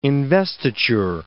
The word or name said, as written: investiture